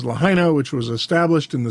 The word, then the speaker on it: Lahaina: Well, high winds from this hurricane drove the fire rapidly westward into the town of Lahaina. I hope I'm pronouncing that right. It's L-A-H-A-I-N-A, Lahaina, or Lahaina.